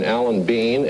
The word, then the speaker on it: Alan Bean: And here's one of the men who was aboard the last time, Captain Alan Bean, astronaut on Apollo 12.